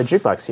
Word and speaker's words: jukebox: You're one of the few people I know who pronounces jukebox correctly. – What do you hear? – Most people say jukebox. – Jukebox. – No one says that. – Yeah, in Wisconsin, everyone would say jukebox. – What? – Jukebox. – What? – Like, very few people would ever pronounce the K.